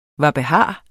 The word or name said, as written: hvad